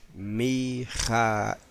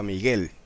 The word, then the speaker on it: Mijael: Ahora, en hebreo se escribe Mem, Yod, Kaf, Aleph, Lamed, Mijael. ¿Quién es como Elohim? En árabe, lo tengo escrito ahí, pero no lo puedo leer, pero sí sé por la transliteración que se dice Mijail. Mijail. ¿Quién es como el poderoso? Correcto. En griego se escribe, se dice igual, Mijael. Ah, y en latín se dice igual, Mijael. Solo en español fue que se transformó un poco la palabra y se dice ahora Miguel.